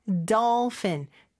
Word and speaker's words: dolphin: 그 다음에 dolphin, 돌핀이 아니죠? doll 어 발음이 더 가깝고 F 발음으로 fin 해주셔야 돼요.